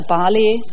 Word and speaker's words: Barley: Also mein Vater ist Brite, also man spricht es Englisch aus, wenn man es korrekt aussprechen möchte, also Barley.